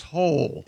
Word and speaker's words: Hole: But it's pronounced Hole. – Yeah, Ole. – Detective A. Hole. Yeah. – It's spelled H-O-L-E.